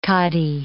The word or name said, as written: khadi